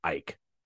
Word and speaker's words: Icke: It's pronounced Icke.